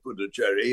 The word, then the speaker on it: Puducherry: But one of my favourite parts of that whole series was when they took you, and I'm going to pronounce this wrong, is it Puducherry, to see a regional... – Pondicherry. I think the new pronunciation is probably Puducherry. Yes, I think it is. I'm afraid I stick to the old pronunciation because I can't get my tongue around the new one.